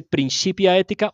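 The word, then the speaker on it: Principia Ethica: Usted que es un latinista, corríjame, ¿eso se dice principia ética o principia ética? – A mí, mi profesora de la Universidad de los Andes me dijo que la C en latín se pronuncia como Q, Principia ética.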